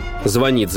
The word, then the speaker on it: звонит: Или звонит звонарь, звонят в звонок, чтоб ты запомнить верно смог.